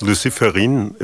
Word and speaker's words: Luciferine: And your project is Luciferine, if I pronounce that right. What is behind that Luciferine? – Yeah, exactly. Luciferine is the name of the molecule that was discovered by a French scientific that's named Raphaël Dubois.